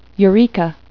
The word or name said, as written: eureka